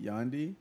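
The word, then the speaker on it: Yandhi: But that's how you pronounce it, Yandy? – It's like Gandhi, but with a Y, because his first one was Jesus.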